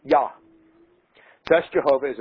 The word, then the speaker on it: Yah: Because, as you all know from always reading of Hebrew names and so forth, they don't have J, they don't pronounce J's, it's Yah. Yah is how they pronounce it.